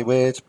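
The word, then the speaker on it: Wyrd: I would say weird, but that's just me.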